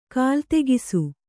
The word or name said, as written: ಕಾಲ್ತೆಗಿಸು